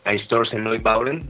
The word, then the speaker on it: Einstürzende Neubauten: Fue un grupo de industriales de finales de los 70s, principios de los 80s, al estilo de Einstürzende Neubauten, no sé si lo pronuncié bien, es alemán. – Sí, sí, así es.